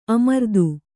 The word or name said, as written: ಅಮರ್ದು